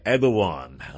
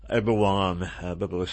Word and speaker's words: Erdogan: When, in early February, Erdogan, so E-R-D-O-G-A-N, most people in this country pronounce it Erdogan, which I used to do with the correct pronunciation is actually Erdogan or something like that, something similar.